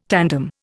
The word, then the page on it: tandem: Indian